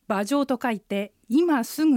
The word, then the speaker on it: 马上: 馬上は漢字で書くと馬の上、馬上と書きます。馬はアクセントの練習出てくるあの馬の三声です。下がって上がります。上は四声、上から下がります。三声四声の組み合わせです。